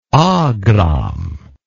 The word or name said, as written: agram